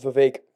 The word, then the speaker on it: Vivek: Cake rhymes with Vivek.